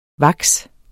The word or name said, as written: vaks